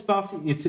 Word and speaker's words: Spatie: Is there a t sound at the end of the name of your company or not? Is it purely just Spatie? – Yeah. – Sometimes I hear a little T and sometimes I don't. So no, it's Spatie. It's like your pronunciation for Spatie is like 10 out of 10. It's perfect.